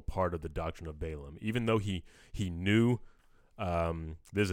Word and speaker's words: Balaam: So first thing I want to talk about is the doctrine of Balaam. I'm saying that right, yeah? – Yeah. – I keep, Balaam.